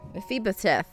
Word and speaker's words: Mephibosheth: Mephibosheth. – Mephibosheth, son of Jonathan and son of Saul, came to David and fell on his face and did obeisance. David said, Mephibosheth.